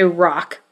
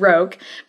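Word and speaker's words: roc: Pretty soon, down, down, down from the mountain comes a rock, which I mispronounced the last episode. I said roke, but it is rock and is a giant eagle-like creature.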